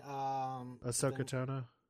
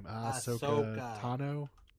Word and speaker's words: Ahsoka Tano: Ahsoka Tano? – Yeah. – Wait, say that again? – I can't pronounce her name. Ahsoka Tano? – Yeah, that's better.